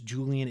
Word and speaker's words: Julian: Julian is spelled JULIAN.